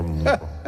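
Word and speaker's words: Moreau: Es el, a ver, que lo busco aquí, doctor Moro. – ¡Es Murro! – ¿Qué? – ¡Murro! ¡No, Moro!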